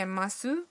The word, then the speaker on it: ます: مصوت او در سوی فعل ماسو بی صداست و این فعل به شکل ماس تلفظ میشه.